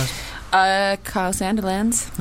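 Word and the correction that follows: Sandilands: That's such a lot of It's actually Kyle Sandlands, but your friend said Sanderlands when you're younger and you said it wrong.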